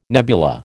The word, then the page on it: nebula: American English